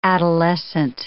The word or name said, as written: adolescent